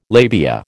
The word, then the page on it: labia: American English